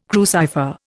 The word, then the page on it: crucifer: Indian